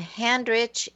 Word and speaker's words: Handrich: And my last name, Handrich, it's just like it sounds H A N D.